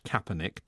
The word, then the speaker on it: Kaepernick: In 2017, NFL player Colin Kaepernick was left without a team after leading the Take a Knee protest. We've all tried, and I think I just about got it right, pronounced Kaepernick, but how do you spell it? – It's a K, isn't it?